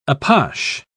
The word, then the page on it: apache: British English